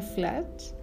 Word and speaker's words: Flush: Hoy día les quiero comentar el libro Fluch, o la pronunciación en inglés sería Flash, de Virginia Woolf.